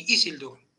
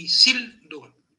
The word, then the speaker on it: Isildur: Otra forma en la que puede ser fuerte la segunda sílaba y llevarse el acento, cuando termina en consonante. Por ejemplo, Elendil. Isildur. No Elendil ni Isildur. Elendil y Isildur. Porque la segunda es fuerte.